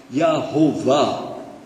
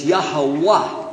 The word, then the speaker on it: Yahweh: So, Jehovah is definitely an English pronunciation of the word Yahweh. It's Yahweh without any vowels. Non-Jews added vowels and said, well, it's probably Yehovah. But it's Yahweh. Wah.